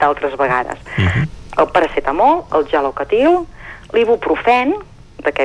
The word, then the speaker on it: paracetamol: Per tant, a veure, eh, recordem, eh, sobretot les pronúncies, per exemple, el paracetamol, no, no parlem d'un paracetamol, eh, vigilem, és un paracetamol i per tant aquesta C l'hem de pronunciar en català eh?